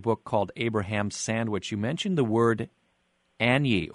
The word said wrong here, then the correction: ayni: I'm not sure how it's pronounced. What is Anyi? – It's actually pronounced Aini. The word is spelled A-Y-N-I.